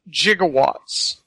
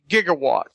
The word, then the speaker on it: gigawatts: But this is also where we start the whole gigawatt thing, where it's in the script, it's spelled J-I-G-O, watts. And it's because, I guess, Bob Gale and Zemeckis talked to somebody who they thought was a scientist and pronounced it gigawatts. And it's actually gigawatts.